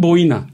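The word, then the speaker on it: Boine: O zaman nasıl telaffuz edildiğini bilmiyordum e, Mari Boynes demiştim. Mari Boyna diye telaffuz ediliyormuş.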